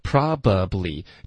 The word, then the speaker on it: probably: 不用嘟嘴了，就直接一个 ya, 结尾顶舌尖 you'll, you'll, 后面 probably, 这三个音节其实现在我们都会把它弱读成两个音节。 Probably, 不用这个中间的 b 的这音节就可以把它给省了。